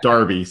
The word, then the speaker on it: Derby: Yeah, but it's spelled with an E. And they call it Darby, so go figure.